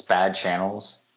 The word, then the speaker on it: Bad Channels: Even though they don't pronounce the X, I think it's just Bad Channels. – It's Bad Channels.